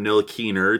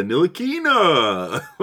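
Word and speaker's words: Ntilikina: He did eventually move on from Nil Keener to Nil Keena, putting like the long A at the end.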